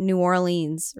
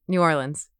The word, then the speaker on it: New Orleans: Or the proper way to say New Orleans, which is not New Orleans, right? – Yeah, New Orleans. Yeah. – Did I do it right? – You did do it right.